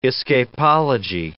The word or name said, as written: escapology